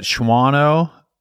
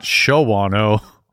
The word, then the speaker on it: Shawano: It was at Shawano, not Shawano or Shawano. Anyway, it's one of those unpronounceable towns. At Cecil Fest in the year 2003, right? Shawano. Leave out silent W.